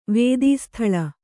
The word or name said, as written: ವೇದೀಸ್ಥಳ